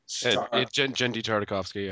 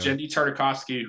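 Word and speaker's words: Genndy Tartakovsky: One, the Tartakovsky, I'm not sure how you pronounce him. – Jendi Tartakovsky, yeah. – Jendi Tartakovsky, who, by the way, topic for another podcast, but Samurai Jack is coming back.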